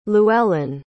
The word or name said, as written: llewellyn